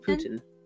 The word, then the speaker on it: Putin: Putin. Putin. Putin. uh, it's emphasis on the first syllable rather than the second, but at least we have that.